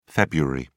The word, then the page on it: feb: British English